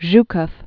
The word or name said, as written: Georgi Konstantinovich Zhukov